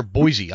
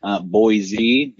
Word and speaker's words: Boise: But if you say a Boise, I think you're saying it right, I think.